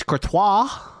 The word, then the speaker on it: Courtois: And I showed it and she was like, Courtois? – Yeah. – And I was like, better, but it's Courtois.